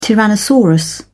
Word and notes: tyrannosaurus: British English

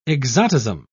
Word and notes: exotism: British English

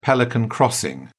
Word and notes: pelican crossing: British English

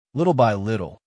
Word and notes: little by little: American English

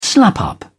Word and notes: slap up: British English

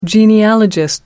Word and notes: genealogist: American English

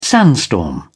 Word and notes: sandstorm: British English

